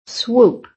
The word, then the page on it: SWOOP: American English